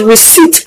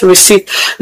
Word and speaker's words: receipt: that the word is pronounced receipt and not receipt.